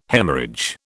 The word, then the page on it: Haemorrhage: British English